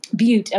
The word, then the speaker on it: Butte: I'm going to say this wrong, Butte County residents? Butte, okay.